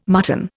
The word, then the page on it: mutton: Indian